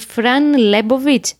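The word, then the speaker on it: Fran Lebowitz: Ε, την Φραν Λέμποβιτ. Σωστά την προφέρω. – Ναι.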